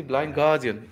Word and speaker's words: Blind Guardian: ¿cómo se pronuncia correctamente Blind Guardian? – Así tal cual, sí, Blind Guardian.